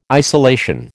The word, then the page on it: isolation: American English